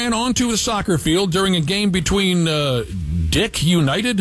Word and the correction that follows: DC United: Oh, DC United.